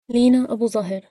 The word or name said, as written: Leena Abouzahr